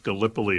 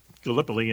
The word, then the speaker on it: Gallipoli: And what it was is it's actually, I think, pronounced Gallipoli. It's a place in Turkey.